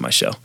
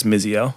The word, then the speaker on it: Michelle: I call it like Michelle, which people don't notice her name is M-I-Z-Z-I-L on Instagram. Her name's actually pronounced Michelle. – Huh. – Everyone thinks it's Mizziel.